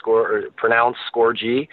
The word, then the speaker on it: Scorgie: Yeah, so if you want to follow me on Twitter, it's Adam Score, like a goal, and then a big G, because that's how my name's pronounced, Scorgie.